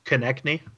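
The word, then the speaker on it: Konecny: And then, I can never pronounce the guy's name from Philly, but Cosini, that's what it looks like it is, but how do you pronounce it? – Konechny.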